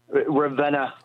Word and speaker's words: Ravenna: Ravenna, Ohio, you let me know how you say it. Welcome to the program. – You got it right the second time there. – Thank you. – Ravenna, Ohio.